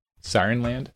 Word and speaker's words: Sirenland: So listeners know it's spelled S-I-R-E-N-L-A-N-D. Sirenland? – Sirenland.